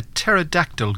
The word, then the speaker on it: pterodactyl: Why can't you hear a pterodactyl going to the bathroom? – I don't know. – Because the P is silent.